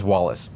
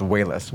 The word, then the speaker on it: Walas: I always thought for years and years and years and years his name was Wallace, but it's actually pronounced Wayless, which I didn't know until recently doing the research myself.